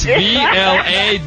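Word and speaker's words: Vlade: Vladae. Sorry, guys. Vladae.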